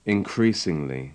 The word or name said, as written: increasingly